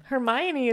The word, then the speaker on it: Hermione: Hermione? Oh, man. – Hermione is how you say it, though.